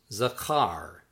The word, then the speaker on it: zakar: Word 52, remember, is pronounced zakar, zakar, zakar.